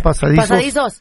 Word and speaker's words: pasadizos: Hay signos, hay imágenes, hay pasadijos. ¿Cómo se dice? – Pasadizos. – Iba a decir pasadizos, son pasadizos secretos.